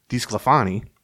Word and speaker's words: DiSclafani: So the Twins' investment in DiSclafani, I said it right that time.